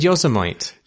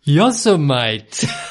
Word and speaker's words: Yosemite: I said Yosemite. – Yosemite.